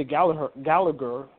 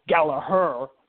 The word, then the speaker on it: Gallagher: Oh, I say Gallagher because that's the way how you pronounce it, but apparently WWE wants to pronounce it Gallagher for whatever reason because the H is usually silent, but that it is what it is.